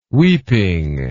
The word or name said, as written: weeping